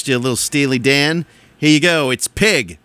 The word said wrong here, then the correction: Peg: I'm sorry, a Peg. Peg. P-E-G. Peg. Rhymes with leg.